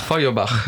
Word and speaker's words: Feuerbach: Feuerbach.